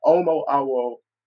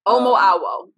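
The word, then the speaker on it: Omo Awo: and forgive me if I mispronounce it, a Omu, say me one more time, an Omu Awu, a new priest in the African spiritual system of Ifa? – Yes, this Omu Awu.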